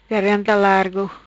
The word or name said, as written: Garganta Largo